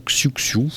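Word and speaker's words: Xiu Xiu: J'ai nommé les Chouchous, ou encore Xuxu pour la prononciation française,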